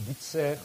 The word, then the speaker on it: Bucer: On prononce Bucer à l'époque. Sur la gravure, vous voyez qu'il y a deux C. Aujourd'hui, on n'en met plus qu'un.